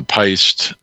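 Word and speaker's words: péist: And I'm pretty sure I got that pronunciation right. This word is P-E-I-S-T-E. Yeah. So that's an Irish word for like a beast or a monster, you know.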